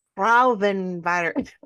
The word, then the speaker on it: Frauenverein: Frauenverein. I tried to do the rolling of the R's, and I just, got partway through that first syllable and was like, nope, this isn't working.